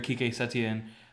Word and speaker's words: Quique Setién: You know, today they beat Real Betis 3-2, and with the new manager, Quique Setién, however you say it.